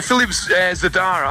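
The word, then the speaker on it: Philippe Zdar: I can't pronounce Philippe Zadar. Is that the best pronunciation of the producer? – Philippe Zadar. Aye, Zadar. That's his name. Yes, aye.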